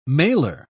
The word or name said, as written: mailer